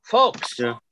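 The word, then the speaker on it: Joe Fulks: But on this exact date, in 1949, the Philadelphia Warriors, Joe Folks, is that how you pronounce his name? – Yeah, Folks.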